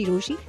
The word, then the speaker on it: Rochie: मेरा नाम है मिर्ची रोशी, नाम की स्पेलिंग है ROCHIE, and today is my rant day.